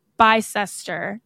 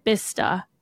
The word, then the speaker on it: Bicester: It's spelled B I C E S T R, like Bicester, and they pronounce it Bista because it it's no like sense.